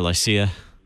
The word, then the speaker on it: Lycia: Myra or Lycia? – No, you did a very good job pronouncing those, by the way.